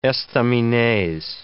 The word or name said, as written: estaminets